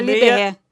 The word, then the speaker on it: Liberre: L-I-B-E-R-R-E. Episódio 86. Nem sei se é assim que se escreve, mas pode falar Liberê.